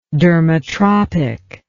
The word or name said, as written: dermatropic